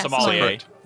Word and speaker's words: sommelier: I always pronounce this incorrectly, I believe. Sommelier? – Yeah, Sommelier. – Sommelier. – Sommelier. – Got it right.